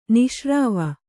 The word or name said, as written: ನಿಶ್ರಾವ